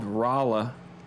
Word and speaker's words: Rolla: So I would also say, I would have say a town called Rolla. – Rolla. – Raw. That's that's spelled Rolla.